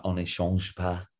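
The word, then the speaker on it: On ne change pas: Am I saying that right? – Yeah, Oni Change Pass.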